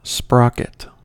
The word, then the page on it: sprocket: American English